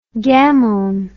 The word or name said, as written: gamone